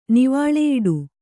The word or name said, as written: ನಿವಾಳೆಯಿಡು